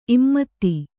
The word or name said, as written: ಇಮ್ಮತ್ತಿ